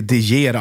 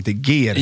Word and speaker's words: De Geer: Inte De Geer alltså, utan De Geer. – Ja. – Som man uttalar.